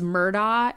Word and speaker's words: Murdaugh: It's Murdoch is it's or Murdoch is it how it's pronounced, but it's spelled Murdoch, Murdaugh.